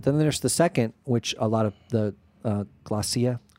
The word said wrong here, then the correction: glossolalia: what is it? – Glossolalia. – Thank you, glossolalia. I knew there was another syllable on there.